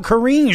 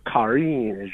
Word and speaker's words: Karine: Sounds like you want to talk about Karine Jean-Pierre. – You got the first name wrong. Oui, is Karine Jean-Pierre.